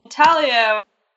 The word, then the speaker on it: intaglio: Intaglio, which is, if you were to say it phonetically, comes out as intaglio. it's an Italian word, so the G is silent.